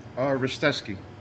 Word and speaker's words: Ristevski: Ristevski.